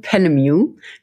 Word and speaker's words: Penemu: Also es wird ausgesprochen Penemu. Wer sich das nicht merken kann, ich sage immer wie Music.